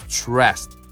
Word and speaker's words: stressed: このストレス度の TRE のところはもう T から R なので、もうチュッ、ね、 stressed, stressed。で、あとはこの stressed のこう D って書いてあるけど、発音は stressed T なんだよね。